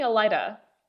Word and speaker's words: Elaida: I was thinking Elaida. – That's actually the correct pronunciation.